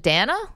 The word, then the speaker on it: Dana: Wait, so it's your name is D-A-N-A. Do you pronounce it Dana? – Dana.